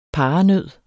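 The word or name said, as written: paranød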